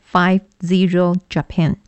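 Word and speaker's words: 00000JAPAN: 啊，日本官方说呢，这是日本呢他们因应大规模灾害之后所设置的这个免费公用 WiFi，统一名称，好，读作 50 Japan。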